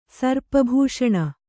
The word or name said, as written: ಸರ್ಪಭೂಷಣ